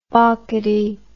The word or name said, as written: bockedy